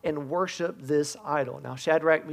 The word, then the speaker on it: Shadrach: It's about three guys named Shadrach, Meshach, and Abednego. And that's really how you say it.